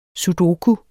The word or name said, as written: sudoku